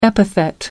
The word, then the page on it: epithet: American English